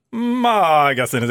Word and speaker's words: Magasinet: Hur uttalar du magasinet? – Magasinet i P3. – Ja, där var det jag. – Vad sa jag? – Magasinet i P3. – Det var så... Magasinet i P3. – Ja, det var långt A.